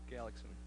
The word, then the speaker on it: Galaxian: Galaxian. – Okay, Galaxian, right. I mispronounced it. That's correct, though.